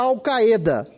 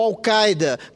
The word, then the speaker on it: Al-Qaeda: Não poderíamos conceder aqui asilo político a um membro da Al-Qaeda, ou Al-Qaeda, dependendo da pronúncia, senador Benedito de Lira.